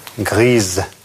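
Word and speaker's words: grise: Серый – «гри», «серая», «гриз» в результате добавления «э».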